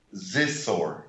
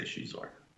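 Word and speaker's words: Xizor: You pronounce it like the Chinese she. – Okay, so I always pictured it in my head Zizor. – I think in the novelization they say Shizor.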